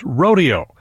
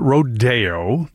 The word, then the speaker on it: Rodeo: Some people call it Rodeo, but Copland never pronounced it that way. The ballet company that premiered the work was sort of horrified to be doing something so American and lowbrow, as they saw it anyway. So they adopted a Spanish pronunciation to make it seem fancier to their fancy dance friends.